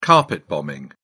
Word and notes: carpet bombing: British English

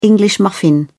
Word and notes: english muffin: British English

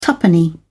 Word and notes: tuppenny: British English

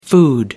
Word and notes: food: American English

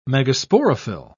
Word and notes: megasporophyll: British English

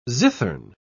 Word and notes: zithern: British English

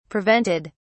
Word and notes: prevented: British English

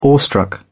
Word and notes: awestruck: British English

